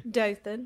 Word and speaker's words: Dothan: In Dothan, Alabama. – Yes. – Did I say that correctly? – Dothan, yes.